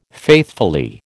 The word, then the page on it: faithfully: American English